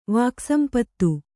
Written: ವಾಕ್ಸಂಪತ್ತು

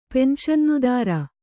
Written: ಪೆನ್ಶನ್ನುದಾರ

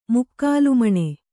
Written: ಮುಕ್ಕಾಲುಮಣೆ

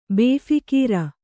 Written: ಬೇಫೀಕೀರ

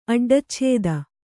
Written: ಅಡ್ಡಚ್ಛೇದ

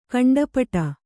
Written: ಕಂಠಪಾಠ

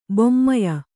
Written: ಬೊಮ್ಮಯ

